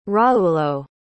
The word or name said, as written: raulo